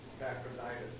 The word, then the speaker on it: Epaphroditus: Epaphroditus.